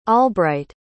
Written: albright

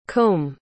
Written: cum

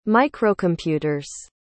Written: microcomputer's

